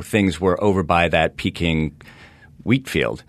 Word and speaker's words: Pekin: And you're saying Peking, not pecan, the nut. – It's spelled P-E-K-I-N, but it meant Peking at the time.